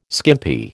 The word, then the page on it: skimpy: American English